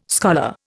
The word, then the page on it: scholar: Indian